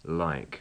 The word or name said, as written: like